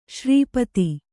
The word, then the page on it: ಶ್ರೀಪಾದ: śrī pāda